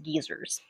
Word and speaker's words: guisers: In Scotland, the groups of young men were called, it's spelled G-U-I-S-E-R-S, but it's pronounced geezers.